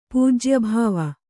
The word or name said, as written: ಪೂಜ್ಯಭಾವ